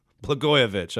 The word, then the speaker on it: Blagojevich: That, I guess, because he pardoned Bladanovich, I guess. I should have brought his name with me. I knew I'd forget how to say it. Blagojevich, I believe that's how you say it.